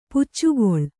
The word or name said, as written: ಪುಚ್ಚುಗೊಳ್